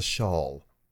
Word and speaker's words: shawl: A shaw is how you actually pronounce that word. – No. – Yes. – No. Shawl. – Yes. – Not normal Americans. – Yes. An American stage standard, the way that people on network news stations speak and you speak on the stage, is not pronounced a shawl.